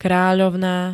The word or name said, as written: kráľovná